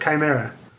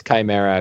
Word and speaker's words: Chimera: It's pronounced Chimera. – Let's check D&D Beyond on that too. – Although I grew up pronouncing that one wrong, and then I saw it in a movie or something. – Yeah, I was doing Chimera. Yeah. So yeah, it's Chimera according.